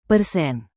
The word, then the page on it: persen: indonesian